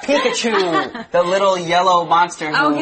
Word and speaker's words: Pikachu: Pikachu, okay, yeah.